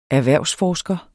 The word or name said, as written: erhvervsforsker